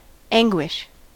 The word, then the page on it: anguish: American English